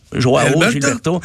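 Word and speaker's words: João Gilberto: Euh, au mois de juillet, le 6, il y a eu, J'essaie de le prononcer, non ben, Yoao Gilberto, non Joao Gilberto.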